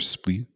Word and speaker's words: Split: Like later on, Split. Is that how you pronounce it? Split mm-hmm. or Split? – It's Split.